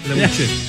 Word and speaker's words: La Bouche: Vocalista de La Bouche. ¿Así se dice o no? La Bouche. La Bouche. – La Bouche. – Petit Bouche, ya. – La Bouche.